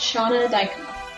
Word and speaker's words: Shana Dykema: My name is pronounced Shana Dykema.